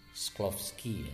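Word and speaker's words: Shklovsky: Sklovsky Sklovsky ya bacanya seperti itu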